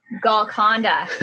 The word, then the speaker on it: Golconda: Golconda.